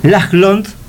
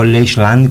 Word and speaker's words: Lachland: Lachland, así se pronuncia, y no Lachland, como yo lo americanicé.